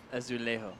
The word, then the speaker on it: Azulejo: Coming in at number 10, the La Naranja Reserva Azulejo. Did I say that right? – Azulejo. Azulejo.